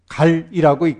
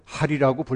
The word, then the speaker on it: 할: 할과 방이라고 얘기하는데 할은 본래 갈이라고 읽지만 옛날 발음대로 할이라고 불교에서는 사용합니다.